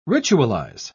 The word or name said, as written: ritualize